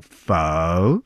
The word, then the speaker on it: pho: it's pronounced like an E at the end, but like you're going up and down. So pho.